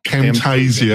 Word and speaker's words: Camtasia: Camtasia.